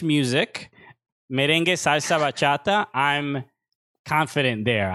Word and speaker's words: merengue: And also, I just want to clarify, it's pronounced merengue. It's merengue.